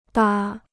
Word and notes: 搭: dā

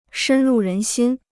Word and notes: 深入人心: shēn rù rén xīn

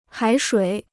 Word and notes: 海水: hǎi shuǐ

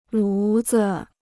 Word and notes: 炉子: lú zi

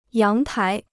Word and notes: 阳台: yáng tái

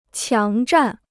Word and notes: 强占: qiáng zhàn

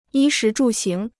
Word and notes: 衣食住行: yī shí zhù xíng

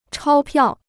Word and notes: 钞票: chāo piào

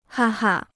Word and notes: 哈哈: hā hā

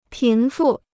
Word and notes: 贫富: pín fù

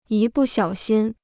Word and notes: 一不小心: yī bù xiǎo xīn

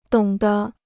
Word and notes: 懂得: dǒng de

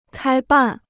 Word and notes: 开办: kāi bàn

